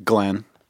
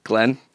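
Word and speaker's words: Nglenn: Glenn. – Huh? – Glenn. – Two N's? – Three. – Whoa. – Where I think they are? – No, the first one is silent. It's N-G-L-E-N-N.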